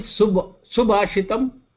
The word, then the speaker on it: Subhashitam: What is Subhashitam? Su means good. Bhashitam means the verbs. That is the pronunciation.